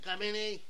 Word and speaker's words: Khamenei: Said Mojtaba Khamenei, acredito. – Khamenei.